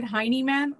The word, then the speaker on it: Heineman: All right, let's get into Emil Heineman. I hope I'm pronouncing this correctly. Somebody said Heineman, like like Buttman, um, and I, I really appreciate that that that help.